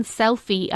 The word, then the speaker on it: Selphy: The one that we had last Christmas, or maybe it was Christmas before, was the Canon Selfie, and it's spelled S-E-L-P-H-Y, and that is a compact photo printer.